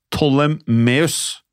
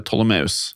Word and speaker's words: Ptolemaios: Uh, det skrives P-T-O-L-E-M-A-I-O-S. Den tolvte. Jeg har forstått det sånn at det uttales Ptolemeus. – Ja, Ptolemaios. – Ptolemaios, den tolvte.